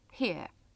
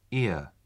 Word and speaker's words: here: تلفظ این کلمه here یه کمی مشکله. حالا چطوره تمرین تلفظ کنیم؟ اول صدای آخره کلمه here. – Ear. Ear. – همین صدای آخره کلمه here در انگلیسی خودش یه کلمه دیگه است. یعنی گوش. تمرین کنید. – Ear.